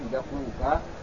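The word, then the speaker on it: دقوقا: ثم ذكر بعض مفردات الحديث في قوله بدقوقة، هذا اسمها وضبطها الشيخ، قال بدال نمل وضم القاف كما أنطقنا بدقوقة وسكون الواء بعدها قاف مقطوع إلى آخره، هي بلد بين بغداد وأربيل في العراق.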